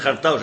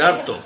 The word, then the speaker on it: harto: Yo estoy hartao. – No se dice jartao, se dice harto.